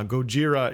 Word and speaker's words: Gojira: Uh, Gojira is the uh, is was the original pronunciation of Godzilla, the original name of Godzilla in Japanese uh, and they're kind they're kind of math metally.